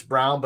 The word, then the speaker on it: Braun: I'm just learning that – apparently Christian Braun's name is not Christian Braun. It's Brown, but it's spelled Braun, but it's pronounced Brown.